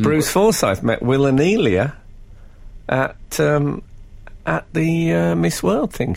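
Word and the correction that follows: Wilnelia: She was interesting pronunciation of Will Nelia. – Oh, is it? – Will and Nelia. – You said it as if it was two people, Will and Nelia.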